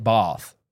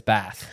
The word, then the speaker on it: Bath: I'm just saying, we had an expert in the dialect come in and say, I understand the instinct is to say it Bath, but it's Bath. That's how the city is pronounced.